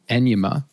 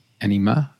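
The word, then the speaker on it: Anyma: And there's a new sort of a pipeline tool called, well, it depends how you pronounce it. I've heard people call it Anima, but it's A-N-Y-M-A. Anima, I mean.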